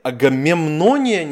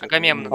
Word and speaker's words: Агамемнон: Если вдруг кто не в курсе, там рассказывается о царе Агамемноне, не знаю, где ударение ставить. – Агамемнон. – Агамемнон, да.